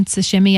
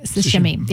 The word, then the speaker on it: sashimi: Sashimi? I think it's sashimi, isn't it? How do you say it? – The I sound is longer. – Oh, okay. Well, it's sushi. – Or the E sound. Sashimi.